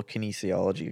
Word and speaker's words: kinesiology: So back to your little kinesiology experiment. Did I say that right? Kinesiology? – Yeah, you're spot on.